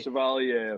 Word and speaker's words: Chevalier: Chevalier affair.